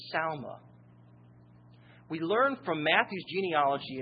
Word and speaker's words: Salma: Phares begat Hezron, and Hezron begat Ram, and Ram begat Amminadab, and Amminadab begat Nahshon, and Nahshon begat Salma. That's how it's pronounced.